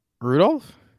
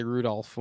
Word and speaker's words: Rudolph: Rudolph? – Rudolph. You sound like an idiot. – Yes, I pronounced the L in Rudolph. – You're not supposed to. It's silent.